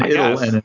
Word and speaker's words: idyll: So is that not how it's pronounced? IDYLL? – That is one of the ways to pronounce it. Idle is also an accepted pronunciation.